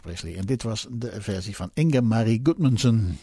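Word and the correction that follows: Gundersen: Ja, Inge-Marie Gunderson. Ik moet het wel even goed uitspreken uit Scandinavië komt ze, dat is duidelijk.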